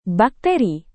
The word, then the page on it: bakteri: indonesian